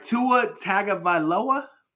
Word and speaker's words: Tua Tagovailoa: Tua Tagovailoa, please tell me I got that right. With all the names I've ever fucked up on this fucking podcast, please tell me I got that right. Tua Tagovailoa.